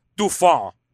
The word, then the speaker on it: Dauphin: It's actually pronounced Dauphin, but I said it looks like dolphin.